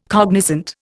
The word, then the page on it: cognizant: Indian